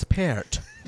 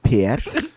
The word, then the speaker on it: Peart: I believe it's Peart. – I believe it's pronounced Peart. – That's been my life's mission, is to just get everybody to say his last name right. – Neil Peart.